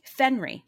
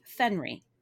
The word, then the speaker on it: Fenry: He was married to Amaryllis Layer, and they also had a three-year-old son named Fenry. – Fenry. – Fenry. Not Henry. Fenry. – But spelled like that? Like Henry with an F? – Yep.